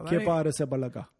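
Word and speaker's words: Kepa Arrizabalaga: Kepa Arisabalaga.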